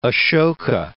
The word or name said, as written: Acoka